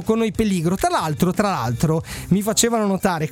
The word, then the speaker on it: peligro: Assolutamente, la parola spagnola si pronuncia peligro e significa pericolo, anche se...